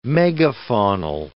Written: megafaunal

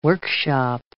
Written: workshop

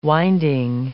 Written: winding

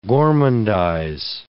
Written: gormandize

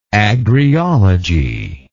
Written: agriology

